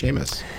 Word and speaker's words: Seamus: It would actually be pronounced Seamus.